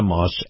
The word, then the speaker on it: Maus: You've got the Moss Nissan dealership, and then right next door to it, you've got the Moss Acura dealership. And Moss is spelled M-A-U-S.